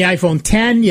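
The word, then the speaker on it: iPhone X: And then the iPhone 10, yes, it's an X, but it's pronounced 10, just like OS 10. It's the iPhone 10.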